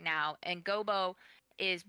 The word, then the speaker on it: Gobo: But Wembley does struggle to find a job, and we come across, is it Gobo? Is that how you pronounce his name? – Yeah, Gobo.